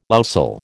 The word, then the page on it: ousel: American English